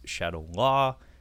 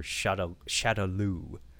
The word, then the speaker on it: Shadaloo: is either pronounced Shadow Law or Shadaloo. I believe it's written in Japanese Shadaloo. – Gotcha. – But in the dub, they say Shadow Law, and it's written in English Shadow Law.